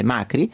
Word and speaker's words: Macri: cioè l'arrivo alla presidenza di Mauricio Macri, perché ha perso l'accento, la I finale, anche se le origini italiane sono ovviamente di Macri, ma è Macri.